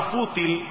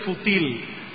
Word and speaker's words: fútil: Por cierto, como estaré usando mucho la palabra fútil, no se dice fútil, se dice fútil.